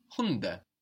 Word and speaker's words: Hunde: The dogs is die Hunde. – Die Hunde. – And we notice also that the D is soft again, because it's not on the end of the syllable like in der Hund.